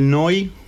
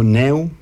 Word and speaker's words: Neu: Bon, ça, c'est un groupe qui s'appelle Noi. Ça se dit Noi ou Neu. Ça s'écrit Neu, mais ça s'écrit N-E-U.